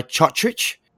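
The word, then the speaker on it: Cotric: And we're doing things, I overheard on one of the Fox programs that Nick Kotrich is no longer going by Chotrich. – Okay, he's changing it back. – Yeah, he's going back to Kotrich. Just Kotrich, yeah. – Kotrich, okay. – Yeah, it's Kotrich now. Relax on the pronunciation.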